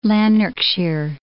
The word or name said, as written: Lanarkshire